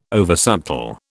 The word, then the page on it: oversubtle: British English